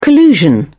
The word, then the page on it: collusion: British English